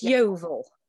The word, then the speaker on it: Yeovil: And how do you pronounce that? – It's Yeovil.